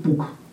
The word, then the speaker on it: пук: Допустим, у сербов, например, там вот ол вот не произносится, оно исчезло. То есть, допустим, полк у них звучит пук. Допустим, волк – вук.